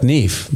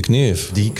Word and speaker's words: Knef: Ich glaube, du darfst sie auch Knef nennen. – Ja, aber das K spricht man ja nicht. – Ja, das ist ja eine Deutsche.